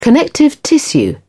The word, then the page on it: connective tissue: British English